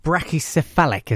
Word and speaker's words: brachycephalic: This is called dogs which are going brachycephalic.